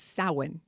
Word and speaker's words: Samhain: Ireland is the home place of Halloween, so there's an entire Home of Halloween exhibit, and they call it It looks like Samhain if you write it, but it's Samhain if you pronounce it properly.